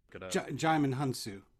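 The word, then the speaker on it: Djimon Hounsou: Jaimin Hunsu.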